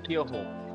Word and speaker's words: Kyoho: Exactamente, se llama Kyoho. Se escribe okay. Con K, Y de yuca, O, H-O.